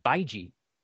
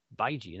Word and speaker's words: Baiji: Started out with the thylacine, the eastern cougar, the passenger pigeon, the Javan tiger, and now number 10 is the Baiji. Baiji, and that's spelled B-A-I-J-I, Baiji, which is a freshwater dolphin that lived in the Yangtze River in China.